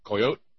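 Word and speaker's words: coyotl: Comes from coyote, and you don't really pronounce the L, C-O-Y-O-T-L.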